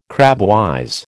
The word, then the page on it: crabwise: American English